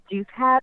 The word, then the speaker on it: Douthat: I say Douthat, or actually, I mean, in my mind, I say douchebag, but Douthat, yeah. I believe.